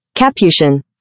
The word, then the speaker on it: Capuchin: And so he writes, by the way, listening now, y'all are pronouncing the word capuchin like the monkey breed. The religious order is pronounced with the emphasis on the first syllable.